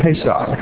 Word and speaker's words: Pesach: Now, I said the word Pesach, and I'll spell it in an English way, P-A-Y-S-A-K. P-A-Y-S-A-K.